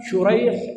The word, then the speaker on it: شريح: فعندنا شريح ابن النعمان وعندنا سريق ابن النعمان، الآن الاتفاق خطا ونطقا فيما يتعلق بمن؟ بالآباء. وحصل فيما يتعلق بالأبناء ما هو؟ الاختلاف في النطق دون الخط. عندنا سريق وعندنا شريح، الخط واحد لكن النطق ماذا يختلف؟